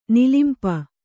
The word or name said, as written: ನಿಲಿಂಪ